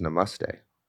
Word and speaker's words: namaste: Apparently it's pronounced namaste. – Get out. – Yeah. I've been saying it wrong.